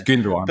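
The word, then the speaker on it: Gündoğan: And you saw it where Jeff Shupp just had tried to sort of, after the ball was already being played towards Gundogan, I can't even say his name. – Gundogan? – Yeah, yeah, anyway. – In Turkish, it's Gundogan.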